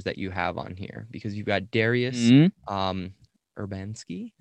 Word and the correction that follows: Dariusz Urbański: Is that how you say it? – those are hard names to spell for you, probably. Dariusz Urbański and Bartosz Jankowski. – You were way off.